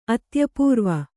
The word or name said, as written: ಅತ್ಯಪೂರ್ವ